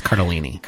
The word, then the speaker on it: Cardellini: I think it's Cardellini. – Cardellini. Is it? – Cardellini. I looked at it myself. – Okay. – I've also always said it wrong.